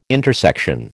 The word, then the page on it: intersection: American English